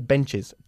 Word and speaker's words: benches: Y dos serían two benches. – Two benches. Muy bien. Y hay que pronunciar esta es como is. ¿Ok? Benches, churches. Lo mismo con watch. One watch, two watches. ¿Ok? – All right.